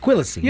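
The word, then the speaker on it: Quilici: I don't even know how to say this. Q-U-I. – Oh, I'll get you. – Quisano? – L-I-C-I. – Oh, Quillacy. – Quillacy. – Yeah, I'm going Frank Quillacy.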